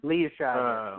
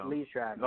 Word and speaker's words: Liev Schreiber: And I'm going to butcher his name because I can never remember how to pronounce it, but is it Liev Schreiber or is it Liev Schreiber? – Liev Schreiber. Liev Schreiber.